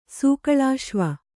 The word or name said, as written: ಸೂಕಳಾಶ್ವ